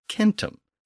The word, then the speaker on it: centum: In Latin, the word for hundred was centum, spelled C-E-N-T-U-M. Keep in mind that the C was always pronounced as a K in Latin. And in Sanskrit, the word was satum, spelled S-A-T-E-M.